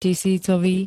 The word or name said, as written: tisícový